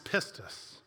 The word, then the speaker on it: pistis: And all of these actions of the client towards their patron, they were known by another Greek word pronounced pistis.